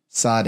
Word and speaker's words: Tsade: And this letter is Tsade.